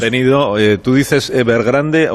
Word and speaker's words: Evergrande: Evergrande, porque es en inglés. – Okay. Sí, sin enfatizar la E final. – Sí, Evergrande.